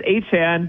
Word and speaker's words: Etienne: And there was a video from his time in college where he said, you know, it actually is Etienne, but the other way is fine too.